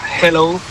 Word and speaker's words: hello: Y la segunda, dile a Elena que su dicción en castellano es la más perfecta que conozco, pero que cuando diga hello, que no pronuncie la J, que no es hello, que es yeah. Hello, que la aspire la J y no pronuncie la J potente castellana.